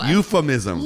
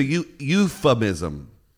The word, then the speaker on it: euphemism: Euthanism. – Hold on. Got it. – It's a euphemism. – Euphemism. – Yeah. You said euthanism.